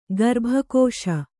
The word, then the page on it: ಗರ್ಭಕೋಶ: kannada